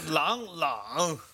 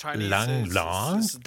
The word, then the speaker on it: Lang Lang: It's actually not the same. It's Lang Lang. – Das klingt nicht gleich. Lang Lang, das spricht man unterschiedlich aus.